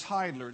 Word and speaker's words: Heidler: To change topic just a bit, I want you to know my last name is Heidler. It's spelled very similar to Ziegler. It has a German EI in the front end of this thing.